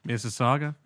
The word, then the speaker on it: Mississauga: Did I say that correctly? – Mississauga. Yeah.